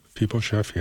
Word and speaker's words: Mephibosheth: That's David and Mephibosheth. Am I saying that right? That's a hard name to say. – Mephibosheth, yeah.